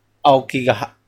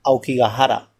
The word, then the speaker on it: Aokigahara: Se llama Aokigahara. Aokigahara. El bosque de Aokigahara. Aokigahara. Sí, o sea, está a los pies del monte Fuji. Aokigahara. Aokigahara. Ahora sí, ya cuando lo va diciéndote... Aokigahara. Aokigahara.